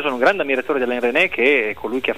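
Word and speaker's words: Alain Resnais: Se posso solo permettermi, si chiama Alain René, la S non si pronuncia, chiuso la parentesi.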